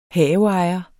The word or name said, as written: HAVEEJERE